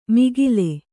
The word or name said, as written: ಮಿಗಿಲೆ